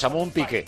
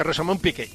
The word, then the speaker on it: Rosamund Pike: Rosamund Pike. ¿Os lo he dicho bien? Rosamund Pike. ¿Sabes quién es? – Rosamund Pike. – Exactamente, Rosamund Pike.